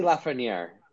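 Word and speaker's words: Lafrenière: Alexis Lafreniere,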